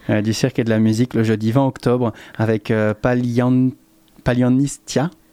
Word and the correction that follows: Palianitsia: Ouais, je prononce bien. – Palianitsia. – Palianitsia, pardon.